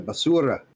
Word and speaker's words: basura: I think I should have trilled my eye there. Basura. – No, you said it right.